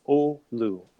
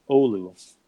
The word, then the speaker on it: Olu: O-L-U, right? – Yeah, so the U is pronounced U, so it's Olu. Olu.